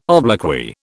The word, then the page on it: obloquy: British English